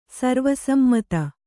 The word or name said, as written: ಸರ್ವಸಮ್ಮತ